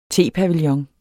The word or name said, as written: tepavillon